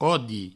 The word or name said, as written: odi